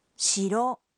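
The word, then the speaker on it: শিরো (城): এই কাঞ্জির উচ্চারণ হল শিরো শিরো।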